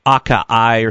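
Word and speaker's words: açaí: And that's the A-C-A-I. Some people say acai or something, but it's pronounced acai.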